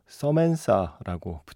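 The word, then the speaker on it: Samantha: 서멘사라고 붙여놨더라고요. 예. 어, 원래 발음에 가까운 곡이지만 약간 낯설게 느껴졌습니다. 저한테는 그냥 사만다인데 사만다로 할게요 편의상.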